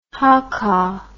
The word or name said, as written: haka